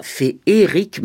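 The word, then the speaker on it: féerique: Mais bon, d'abord, on n'écrit pas « Féric », mais « Fé-E-ric ». Sans accent sur le second E. Et on prononce féric, comme téléphérique. En prosodie, le mot ferry a deux pieds, comme ma grand-mère, et non trois comme escabeau. Si le second E devait être prononcé, on écrirait féhéric. De même qu'on écrit téhéran.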